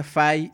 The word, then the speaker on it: Fi: En inglés es Fi, pero se pronuncia Fai, dice Jesús.